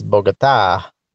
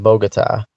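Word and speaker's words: Bogotá: I'm always yelled at by my wife and her family because it's Bogota, not Bogota.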